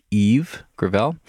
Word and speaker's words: Yves Gravelle: Yes, that is how you pronounce his name. It is Yves Gravelle.